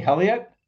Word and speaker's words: Helio: Here we have, how do you say, Helio. – Helio. – Like Helio Castroneves.